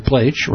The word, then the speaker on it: Pleisch: Steve Pleich, rhymes with H, for those of you who look at his name and wonder how to pronounce it, P-L-E-I-S-C-H,